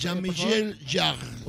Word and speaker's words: Jean-Michel Jarre: Jean-Michel Jarre.